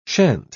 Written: shan't

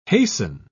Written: hasten

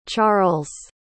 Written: charls

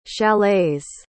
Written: chalets

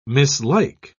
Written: mislike

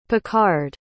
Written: picard

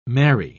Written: marry

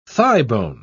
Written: thighbone

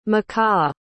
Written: makah

